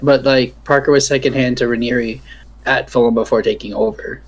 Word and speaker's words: Ranieri: It's an I. – Ranieri. – Yes, it's pronounced that way. You're pronouncing it correctly.